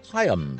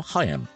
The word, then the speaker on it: Haim: And it was Haim before that. Yes, not Haim anymore, Haim.